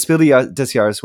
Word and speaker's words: Spiel des Jahres: I was informed that I was pronouncing Spiel des Jahres wrong, that it's a hard S for the des, so I'm working on that. But it's Spiel des Jahres winner, Cascadia.